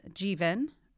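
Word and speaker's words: Jivan: So on Monday, the new city council was inaugurated and we welcomed two new members, Jeevan, rhymes with Steven, everyone, Sabrina Wheeler and Patty Nolan.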